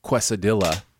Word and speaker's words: quesadilla: And I said something like, I believe you mean quesadilla. And she says, and we have a friend who pronounces it whatever. How do you say it? – Quesadilla. That's what we're going with. The English pronounce it quesadilla.